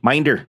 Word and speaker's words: Minder: Oh, Minder, sure. I put the wrong emphasis on the wrong syllable.